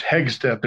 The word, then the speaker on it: Hegsted: It's Danish, Hegsted. There's actually a street named Hegsted. It's after the Hogsted, where the hawks roost in Denmark. So you did great.